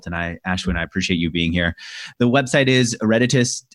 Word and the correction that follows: Eruditus: I feel like I'm mispronouncing your company name. Ereditus. – Eruditus.